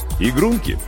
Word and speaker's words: игрунки: «Игрунки. Карликовые игрунки», — делая ударение на «о», — объяснил Гавриил Михайлович и улыбнулся.